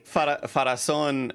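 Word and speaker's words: Pharazôn: Farazan, you put the acento at the final syllable.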